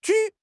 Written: ju3

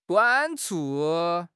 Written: 短促

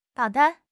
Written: 导弹

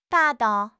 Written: 霸道

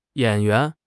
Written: yan3 yuan2

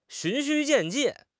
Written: 循序渐进

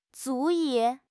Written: 足以

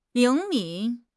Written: 灵敏

